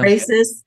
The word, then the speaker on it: ekphrasis: Ekphrasis. Is that how you say it? – Ekphrasis. Yeah. – Ekphrasis.